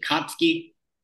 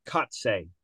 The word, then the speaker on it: Kotsay: How would you pronounce it? Kotsky. – So it's Mark Kotze. K-O-T-S-A-Y.